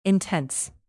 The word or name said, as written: intense